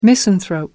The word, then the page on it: misanthrope: American English